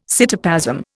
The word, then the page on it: cytoplasm: Indian